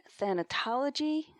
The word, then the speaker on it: thanatology: Again, Amy is a fellow in thanatology. Am I pronouncing that right? – That's correct, yes.